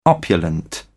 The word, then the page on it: opulent: British English